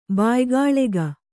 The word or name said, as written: ಬಾಯಿಗಾಳೆಗ